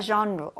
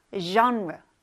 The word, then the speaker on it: genre: 이 장르는 영어로 발음을 할 때 genre 이렇게 합니다. 장르가 아니라 genre. 되게 발음이 이상하잖아요. 맞아요. 이 단어는 불어에서 온 단어이기 때문이에요. 그래서 G를 프랑스 사람들은 이제 z, z 이렇게 발음을 하거든요. 그래서 장르 이렇게 하지 않고 genre, genre 약간 Z 발음이랑 좀 비슷해요. 그래서 genre 이렇게 발음해 주시면 됩니다.